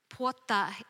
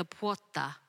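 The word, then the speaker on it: pwoja: Puata is spelt P-W-A-J-O. P-W-O-J-A, spelt nothing like you say it, importantly.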